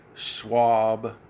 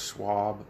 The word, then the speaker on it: Schwab: Klaus Schwab, spelled K-L-A-U-S, Klaus. Schwab is like Charles Schwab, but if you don't know how to spell it, it's S-C-H-W-A-B, Schwab.